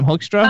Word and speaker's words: Hoekstra: How do you pronounce his name? Hoekstra. Hoekstra. Hoekstra.